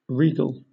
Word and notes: regal: British English

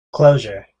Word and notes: closure: American English

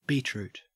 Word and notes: beetroot: Australian English

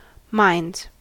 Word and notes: minds: American English

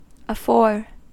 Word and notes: afore: American English

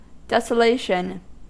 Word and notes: desolation: American English

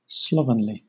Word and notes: slovenly: British English

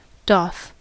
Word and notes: doth: American English

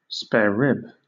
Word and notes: sparerib: British English

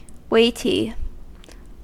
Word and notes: weighty: American English

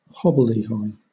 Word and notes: hobbledehoy: British English

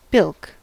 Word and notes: bilk: American English

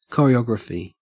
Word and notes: choreography: Australian English

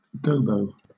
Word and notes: gobo: British English

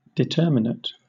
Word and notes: determinate: British English

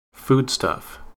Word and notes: foodstuff: American English

